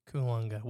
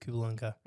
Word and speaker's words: Koolunga: How would you pronounce the word? K-O-O-L-U-N-G-A. – K-O-O-L-U-N-G-A? Mm-hmm. Kulunga? – Kulunga. We'll go with Kulunga.